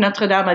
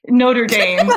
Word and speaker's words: Notre Dame: That be said, I've never been to Notre Dame. I don't know what kind of conversations. Notre Dame.